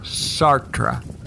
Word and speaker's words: Sartre: How do you say that now? – Sartre.